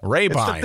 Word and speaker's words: Rabine: Eric, how do you pronounce it again? I always forget. – Rabine. It's Rabine. Thank you.